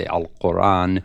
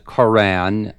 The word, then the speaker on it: Quran: I mean, if you're going to speak Arabic, you would say Al-Quran. – Uh-huh. – But Quran is acceptable in English.